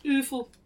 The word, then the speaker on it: UFL: How's that pronounced? Awful. – Awful.